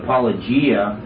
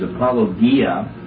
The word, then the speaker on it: apologia: Most people call it apologia, but actually the correct way to pronounce it in the Greek is apologia.